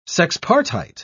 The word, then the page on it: sexpartite: British English